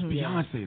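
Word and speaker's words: Beyoncé: Beyonce, right? – Yes. – Yeah. You know what? I was really tired that day. What can I say? – It's Beyonce, though.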